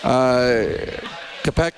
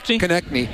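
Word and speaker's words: Konecny: Kopech, how do you say his name? Number 11? – That was the power play. – Yeah, well, yeah, what's his... – Konechny.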